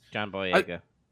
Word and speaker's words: Boyega: John Boyega.